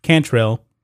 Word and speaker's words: Cantrell: And they're run by LaToya Tranquill. I believe that's the way you pronounce her name. Cantrell.